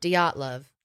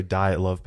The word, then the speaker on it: Dyatlov: Dyatlov. – Dyatlov. A lot of people pronounce it wrong, but they're official.